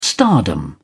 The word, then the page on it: stardom: British English